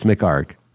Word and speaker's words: McHargue: And then, you know, literally I'm looking at the notes right now and your name is written phonetically for me to remind me do not pronounce those last two letters. It's McArk.